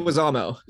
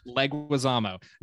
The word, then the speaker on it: Leguizamo: Leguizamo. – Leguizamo.